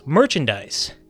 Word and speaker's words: merchandise: Our own Jeff Dorchin replies, the way he pronounces the noun merchandise with a soft S sound, that always seems to me to be reserved for the gerund pronunciation, merchandising. I feel the stuff you sell is merchandise.